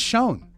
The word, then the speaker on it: Schon: Schon or Schon? – I think it's Schon.